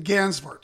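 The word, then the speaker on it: Gansevoort: And this mansion's in Gainsvort. Did I pronounce that correctly? – In Gainsvort. – Gainsvort.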